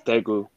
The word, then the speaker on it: Daegu: He's from South Korea, more specifically from Daegu. Did I say that right? – Yes, Daegu. – Okay. Daegu.